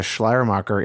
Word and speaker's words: Schleiermacher: But it's interesting that the personal relationship with Jesus thing goes all the way back to Schleiermacher in the 1700s.